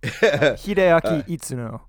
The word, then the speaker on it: Hideaki Itsuno: Hideaki Itsuno.